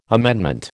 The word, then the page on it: amendment: American English